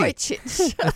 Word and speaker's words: Radojcic: I knew you'd come in and tell me how to pronounce it perfectly. – It's easy. Radojcic.